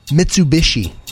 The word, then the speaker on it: Mitsubishi: Mitsubishi.